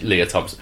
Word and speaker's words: Lea Thompson: Uh, Le- is it Lea Thompson? I'm saying her name right. Um, yes, it is Lea Thompson.